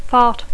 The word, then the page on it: fart: English